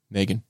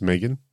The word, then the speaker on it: M3GAN: Megan. – Especially when they would have gone in the movie and it would have been pronounced Megan.